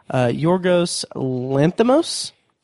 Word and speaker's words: Yorgos Lanthimos: So this movie is directed by, I'm going to butcher this guy's name, Yorgos Lanthimos.